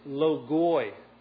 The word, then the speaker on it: logoi: Now that Greek word words is logoi. We would spell it L-O-G-O-I with an O-I ending.